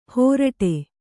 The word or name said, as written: ಹೋರಟೆ